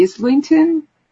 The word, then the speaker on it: Islington: Michael P says, Andrew English is going to Islington.